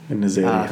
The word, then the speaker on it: En-Nesyri: En-Nesyri? – Hey, do you know how to say it? – Yeah, Youssef En-Nesyri. – En-Nesyri. – That's how we say it back in Morocco.